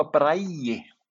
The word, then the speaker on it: Bragi: Bragi, yeah. It's more of a Bragi with a Bragi. – Yeah, more closer to Brian than maybe Bragi. The G is more like I.